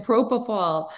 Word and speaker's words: propofol: How do you pronounce that? – You named it propofol.